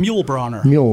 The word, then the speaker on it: Mulebrenner: Mulebrenner. – Jim Mulebrenner. – Like Yul Brynner. Remember the bald guy from King and I? – Absolutely.